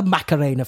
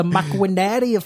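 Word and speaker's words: Maquinaria: It's not the Macarena Festival, it's the Macuinaria Festival, not the Hey Macarena Festival.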